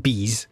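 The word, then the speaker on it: Bease: So Bees, it's B-E-A-S-E, but it's pronounced Bees.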